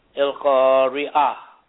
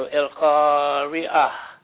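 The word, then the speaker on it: El Khari A (القارعة): El Khari A. And whenever you read Quran and you come to a word that has a tamabuta on its ending, the tamabuta turns into a silent H.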